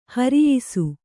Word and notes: ಹಿರಿಯಿಸು: Kannada

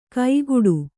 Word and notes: ಕೈಗೂಡು: kaigūḍu

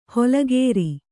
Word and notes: ಹೊಲಗೇರಿ: holagēri

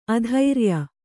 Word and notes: ಆದೊರೆಯ: ādoreya

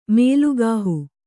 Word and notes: ಮಲಕುಹ: malakuha

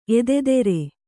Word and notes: ಎದೆದೆರೆ: kannada

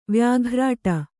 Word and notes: ವ್ಯಾಘ್ರಾಟ: vyāghrāṭa

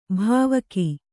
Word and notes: ಭಾವುಕ: kannada

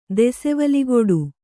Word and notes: ದೆಸೆವಲಿಗುಡು: desevaliguḍu

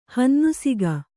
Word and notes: ಹನ್ನುಸಿಗ: Kannada

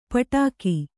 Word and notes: ಪಟ್ಟುಕ: Kannada

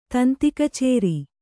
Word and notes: ತಂತಿಕಚೇರಿ: Kannada

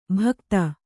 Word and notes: ಭಕ್ತ: kannada

